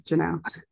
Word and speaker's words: Jeanelle: I'm sorry. Sorry. It's Jeanelle. – Jeanelle? Okay. I was thinking, I was like, am I pronouncing this name wrong? – And no, It's cool. – Jeanelle. Okay. – So yes. – Okay. – Yeah. It's just the A and the Adams messes people up.